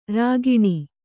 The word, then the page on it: ರುಗ್ಣ: rugṇa